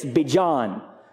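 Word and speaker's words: Bejon: And I got in touch with him to ask him how to pronounce his name, and he said it's Bijon. Not Bijon. Not Bijon. But Bijon. That's how you pronounce it.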